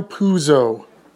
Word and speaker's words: Puzo: Mario Puzzo is how most Italian Americans say his name. P-U-Z-O is actually not Italian, however. The name is most probably P-U-Z-Z-O, Puzzo, in Italy, or P-U-S-O, Puzzo, in Italy.